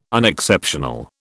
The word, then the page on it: unexceptional: British English